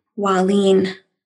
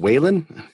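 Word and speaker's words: Wahlin: Waleen. – Dan Waleen. – Yep. Yep. A lot of people want to say they read that and think, is it Waylon? But no.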